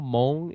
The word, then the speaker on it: Hmong: And for people that don't know, Hmong is actually it's spelled H-M-O-N-G, but it's pronounced M-H-O-N-G. So if you think of that terms, then you can say it.